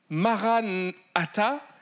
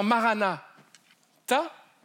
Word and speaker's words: Maranatha: Si tu dis euh, Maranatha, ça veut dire notre Seigneur est venu, il est déjà venu. Et c'est une réalité biblique. Mais si tu le, prononces d'une autre manière, en disant Marana, ta, c'est cette phrase qui dit euh, Viens, Seigneur Jésus, avec un gros point d'exclamation à la fin, en français.